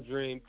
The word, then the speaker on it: Dreaam: Well, one, my name is Vaughn Dreaam, two A's in Dreaam.